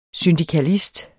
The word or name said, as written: syndikalist